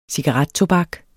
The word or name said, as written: cigarettobak